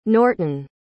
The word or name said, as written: norton